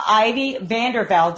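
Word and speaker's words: Ivy Vanderveldt: But anyway, so yes, I have Ivy Vandervell.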